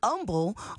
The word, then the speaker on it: Humble: That was Humble by Kendrick Lamar, not to be confused with Humble, which is a part of town here in the H. See how the H is silent when you say Humble versus saying humble to humble yourself?